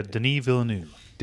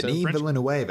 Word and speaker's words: Denis Villeneuve: And just Denis, or whatever y'all say his name is, like... – Is a Denis Villeneuve. – Denis Villeneuve. Denis. He's franchised. – Denis Villeneuve, eh? – French-Canadian.